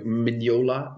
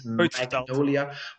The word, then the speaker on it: Mignola: Ja, wat je bedoelt is tegen dan was eh, Mike Mignola, niet Magnolia. Want zeg dat niet no gebruik die uitspraak niet nog eens.